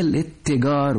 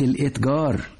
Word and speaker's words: الاتجار: حسنا، ماذا إذن عن كلمة الإتجار؟ – هي الاتجار وليست الإتجار. الاتجار مصدر للفعل اتجر يتجر، التاء فيها مشددة وليست ساكنة، وألفها همزة وصل لا تنطق.